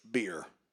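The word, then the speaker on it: bier: And the actual pronunciation of this word is beer.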